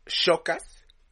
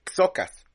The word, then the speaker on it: Xocas: Es que, es que justo hablé de eso ayer con Silent, porque mi dijo, es, Chocas o Xocas, y es Xocas, porque la pronunciación de la X en español se pronuncia como KS. – Ajá. – En nuestro español. Entonces Xocas, no Chocas.